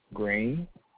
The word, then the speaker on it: Green: Green. – Spell that for me. – G-R-E-E-N. – Oh, like the color.